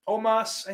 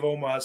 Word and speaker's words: Omos: Omos, I think is how you say it. I think I finally figured that out.